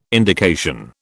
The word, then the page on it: indication: British English